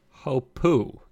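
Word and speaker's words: Hopoo: Hopu. It sounds like it's spelled.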